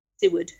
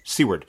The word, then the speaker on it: Siward: is it Siward? – Seward. – Seward.